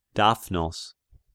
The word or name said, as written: Daphnos